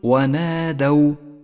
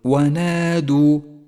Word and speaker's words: ونادوا: المثال التالي احذر من اللحن عندما تقرأ كلمة ونادوا، ونادوا، فالدال مفتوحة وليست مضمومة، قل ونادوا ولا تقل ونادوا، فهو خطأ شائع.